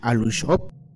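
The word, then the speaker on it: Aluxo'ob: En la lengua maya, el plural de Alush se le pronuncia Alushop.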